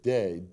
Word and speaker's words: dei: And it used a Greek word, dei. D-E-I is the way you spell it.